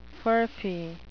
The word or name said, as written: furphy